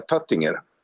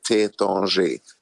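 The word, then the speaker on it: Taittinger: Ja, alltså för mig är ju det som oftast är huschampanjen på Nobelmiddagarna, nämligen, nu uttalar jag det på mitt sätt igen, Tétanger. Men jag tror de flesta skulle säga Tattinger. Jag har lärt mig nu att det heter Tétanger.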